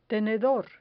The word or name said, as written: tenedor